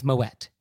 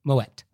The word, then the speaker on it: Moët: So you do say the T. It's Moët. He was Dutch. He wasn't French. So the correct pronunciation is Moët.